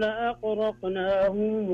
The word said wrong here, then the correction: أغرقناهم: لاحظ شوف الغين بعدين جاء بعدها حرف الراء بعدين جاء بعدها حرف القاف، فما نبغى ننطق الغين كأنها أو القاف كأنها غين، أغرقنا، لا خلها أغرق أغرق أقلها أغرقناهم.